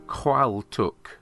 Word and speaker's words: Qualtagh: Now you'll need that spelling. It's spelt Qualtagh, or sometimes Qualtagh. I know. But it is pronounced Qualtuk. What language is that, you say? Well, Qualtuk